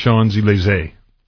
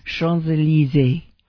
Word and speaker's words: Champs-Élysées: Okay, and so thanks for the info also on the Champs-Élysées. – Close. – Close. How is it pronounced? – Champs-Élysées.